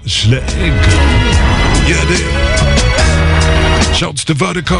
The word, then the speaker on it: Virdiko: And Vertigo is spelled V-I-R-D-I-K-O. That's V-I-R-D-I-K-O.